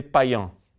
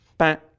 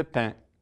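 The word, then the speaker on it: Payns: É mais fácil dizer Payan. Payan. Mas isso daqui em francês, atualmente, se pronuncia somente Pain. Então, os franceses chamam esse sujeito, o fundador dos templários, de Hugues de Payn. Difícil de pronunciar para nós brasileiros, né? Hugues é Hugo de Payn. Uma sílaba só. Payn.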